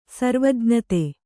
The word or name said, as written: ಸರ್ವಜ್ಞತೆ